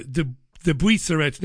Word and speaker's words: de Buisseret: It's de Buisseret. So de Buisseret. You just don't pronounce the T at the end.